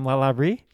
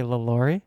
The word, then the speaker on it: Lalaurie: Again, it's Madame, I struggle with it, Madame Lalaurie. – Yes. – Because in New Orleans, we mispronounce it and say LaLaurie, but Madame Lalaurie, the mistress of the haunted house.